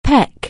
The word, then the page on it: peck: anglais